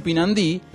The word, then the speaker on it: pinandí: Pinandí, con acento en la última sílaba, aunque al escribir no se pone el acento. Si usted quiere escribir pinandí correctamente, no ponga el acento, pero pronúncielo como una palabra aguda.